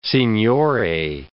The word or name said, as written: signore